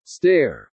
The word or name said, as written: stare